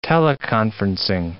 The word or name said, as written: teleconferencing